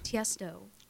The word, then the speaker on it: Tiesto: Tiesto. – Uh-huh. – Am I saying that right? – Yep.